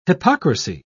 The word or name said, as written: hypocrisy